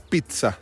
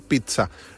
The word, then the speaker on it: pizza: Es pizza, ¿sí? Es P-I-T-S-A, si lo querés pronunciar, pizza. No pizza, no pizza, no pinza, nada, no.